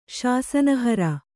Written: ಶಾಸನಹರ

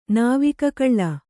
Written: ನಾವಿಕಕಳ್ಳ

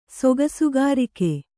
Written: ಸೊಗಸುಗಾರಿಕೆ

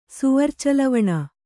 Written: ಸೌವರ್ಚಲವಣ

